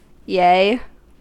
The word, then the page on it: yea: American English